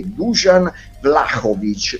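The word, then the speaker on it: Dušan Vlahović: ascoltano la mia trasmissione e mi hanno detto l'assoluta pronuncia è Dusan Vlahovic e quindi io lo pronuncio in questo modo.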